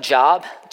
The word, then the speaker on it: Job: You can do that, and just, it's spelled J-O-B, it's not Job, chapter 1 and 2, it's Job, that was his name, Job chapter 1 and 2.